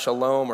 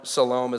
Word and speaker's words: shalom: And we talked about, a quick recap, in the Hebrew word, it's shalom, and it's pronounced shalom, or salom is what some translations have, but shalom.